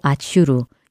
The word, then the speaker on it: 아슈르: 히브리어로 아슈르, 영어로는 아시리아라고 부릅니다. 따라서 영어식으로는 아시리아이고요. 히브리어식으로는 아수르라고 부르는 것이지요.